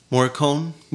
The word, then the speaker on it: Morricone: And what's really interesting about that is that the same composer, I can never pronounce his name, Morricone. – Morricone. Yes. Mm-hmm.